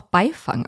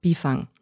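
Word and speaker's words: Byfang: Es wird nämlich mit Y geschrieben. Also ich dachte, vielleicht spricht man das auch Beifang aus. – Mhm. – Ist aber wohl nicht so. – Okay. – Ja, also Biefang.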